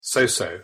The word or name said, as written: so-so